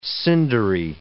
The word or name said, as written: cindery